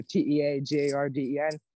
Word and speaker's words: Teagarden: Yeah, all my social medias are Carson Teagarden. My last name is spelled like a cup of tea in the garden, so Teagarden.